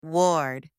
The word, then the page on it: ward: anglais